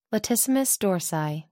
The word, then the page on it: latissimus dorsi: American English